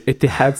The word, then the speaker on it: Etihad: Etihad Stadium.